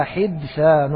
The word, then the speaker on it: حدثان: قال: وحجة الجمهور ما رواه مالك والشيخان أيضا عن عائشة أن رسول الله صلى الله عليه وآله وسلم قال: لولا حدثان، لولا حدثان، هكذا نطقها بكسر المهملة وسكون الدال وفتح الثاء المثلثة،